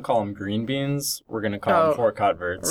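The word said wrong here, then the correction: haricots verts: Rico verts, yeah. – Is that how you say that? – Rico verts, yeah.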